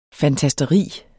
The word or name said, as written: fantasteri